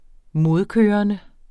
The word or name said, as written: modkørende